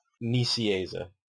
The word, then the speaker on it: Nicieza: Nicieza.